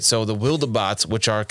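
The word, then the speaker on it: Wildebots: And then the Wildabots show up, which the whole time I read this, I pronounced it Wildbots in my head. – Yeah, so did I. – But then I watched part of the animated movie of this that's on Netflix. And they pronounced it Wildabots. And I was like, well, that makes sense. There's an E in there. It's like Wildebeest.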